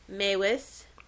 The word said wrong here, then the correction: Meiwes: It's a German last name. Let me see. And it's pronounced Mavis. It's Meiwes, but it's pronounced Mavis.